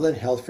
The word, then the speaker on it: Lin Health: Let's talk about Lin Health for a second. So let's back up just for a second. What is Lin Health? Is spelled L I N, correct?